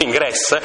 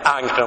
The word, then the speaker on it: Ingres: Il famoso pittore Ingres, quello che si scrive Ingres, a volte in italiano si dice Ingres, ma è Ingres in francese,